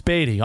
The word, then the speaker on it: Baty: Batty. Dad, it's Baty.